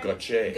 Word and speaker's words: Gotye: I've asked Gotye. Gotye. I think it's a single male singer. – It's okay. Gotye. – Gotye. – Yes. Gotye. Gotye. Gotye. Gotye. Gotye.